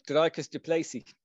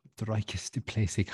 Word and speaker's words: Dricus du Plessis: Oh, Dricus du Plessis. – Dricus du Plessis.